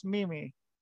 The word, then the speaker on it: Meme: So if anyone needs to know how to spell it, but it's pronounced Mimi, which is what I thought those were called. I thought they were called Mimi.